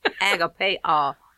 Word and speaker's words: agapao: Well, I think it's agapeo. A-G-A-P-A-O. Agapeo.